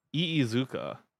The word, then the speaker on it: Iizuka: How would that be pronounced? – Iizuka. – Okay. Okay, I wasn't too far off. – Yeah, you're good.